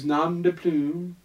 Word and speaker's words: nom de plume: It's about an author who's nom de plume, did I say it correctly? – Yeah, yeah.